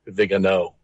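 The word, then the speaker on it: Viganò: First of all, his name is pronounced Vigano. – Okay. – The accent's on the O, so people don't get confused.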